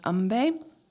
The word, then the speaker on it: Ambe: And today's mantra is Jai Ma Ambe, and that's Umbe is spelled A M B E. I believe it is pronounced Umbe.